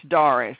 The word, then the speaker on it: Darius: Okay, it's Darius, and it's spelled D-A-R-I-U-S. – D-A-R-I-U-S, Doris.